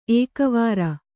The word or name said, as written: ಏಕವಾರ